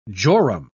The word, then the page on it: jorum: British English